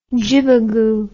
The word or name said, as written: jibagoo